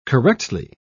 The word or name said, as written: correctly